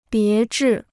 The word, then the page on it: 别致: bié zhì